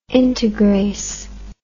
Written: integrase

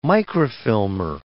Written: microfilmer

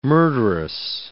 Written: murderous

